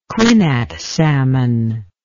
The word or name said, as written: quinnat salmon